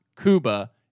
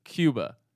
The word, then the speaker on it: Cuba: Now, please, please, please pronounce Cuba Gooding Jr.'s name properly. It's not Cuba, it's Cuba.